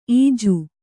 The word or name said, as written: ಈಜು